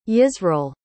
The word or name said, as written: yisreal